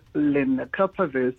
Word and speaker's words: lenacapavir: One of the things that we were struggling with in preparation for this chat is the pronunciation of lenacapavir. Can you help us with that and explain what it is exactly? – You got it. It's lenacapavir.